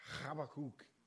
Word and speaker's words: Habakkuk: It's not Habakkuk or Habakkuk. It's Habakkuk. You know, spit was flying when he said it and everything, you know. Real Hebrew guy, great guy. So Habakkuk 3:17 and 18.